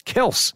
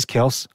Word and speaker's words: Kelce: I mean, Travis Kelce and brother Jason interviewed their dad after hearing somewhere that their surname was actually pronounced Kelce. Their dad just said, yeah, it is Kelce.